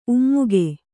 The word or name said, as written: ಉಮ್ಮುಗೆ